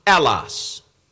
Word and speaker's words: allos: That word another is a Greek word pronounced allos.